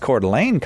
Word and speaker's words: Coeur d'Alene: I can pronounce Coeur d'Alene, Kyle.